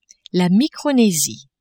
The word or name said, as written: Micronesie